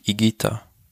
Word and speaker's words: Higuita: Das I, äh, das H spricht man nicht mit. Higuita. – Ja, aber wo ist denn das U? – Ja, das ist auch stimmlos.